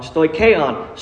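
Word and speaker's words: Stoicheon (στοιχεῖον): Said it wrong. Stoicheon. Stoicheon, it's a word that, again, it's not too familiar with to us, but it's a concept that we can gather.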